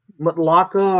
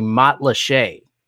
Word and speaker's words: Matlacha: and the pronunciation is French, but the word is Native American. It's a weird thing. So it's actually Matlaché.